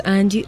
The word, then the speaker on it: Angie: Mi nombre, les recuerdo, es Angie Reyes Melo, arroba Angie Literata. Ese Angie se escribe a n g i e, como la canción de los Rolling Stones.